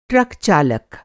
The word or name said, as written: ट्रक चालक